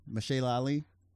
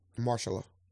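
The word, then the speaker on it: Mahershala: Like, I listened to the Breakfast Club interview of Michelle Ali. – Marshalla.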